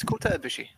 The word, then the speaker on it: Kota Ibushi: His name is Kota Ibushi.